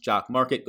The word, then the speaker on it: Jock MKT: It is Jock Market, but if you're looking at it, it's Jock MKT, pronounced Jock Market.